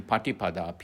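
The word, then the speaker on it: Paṭipadā: The middle path, Majjhima Patipada, M-A-J-J-H-I-M-A, Majjhima, long A, Majjhima, then Patipada, P-A-T-I, diacritical mark, dot underneath T, pa, T, I, P-A-D-A, Patipada, Majjhima, Patipada, the middle path.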